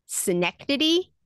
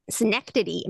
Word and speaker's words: synecdoche: Synecdoche. Am I saying it right? – It sounds right.